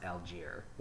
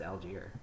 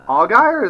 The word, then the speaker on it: Allgaier: Also, that guy's name was pronounced Algier, which I can't even, like, I won't blame you for, like, mispronouncing it, because that's a really weird pronunciation of his name. – Fair. – It's Algier, for some reason.